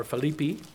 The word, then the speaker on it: Philippi: Philippians chapter 2, Paul writing to the church at Philippi there, or Philippi, as Mr. Johnson likes to pronounce it.